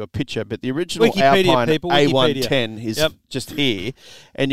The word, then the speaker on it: Alpine: Not Alpine. It's spelled Alpine, but it's an Alpine A110.